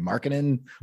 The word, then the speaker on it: Markkanen: I'm in love with some Lauri Markkanen, or however you say his name. – Markkanen.